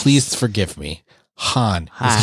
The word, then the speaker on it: Huan: His name is spelled H-U-A-N. – H-U-A-N, Juan. – Yes. That's what I thought. It's Han. – Han. – So I called to talk to him and I said, is Juan there? And they're like, who? And I said, I could be saying it wrong. H-U-A-N. They're like, oh, Han. I'm like, okay.